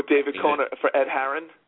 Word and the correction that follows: Ed Hearn: Ed Hearn, yeah. – Oh, Ed Hearn, yeah. – Can't pronounce his name right.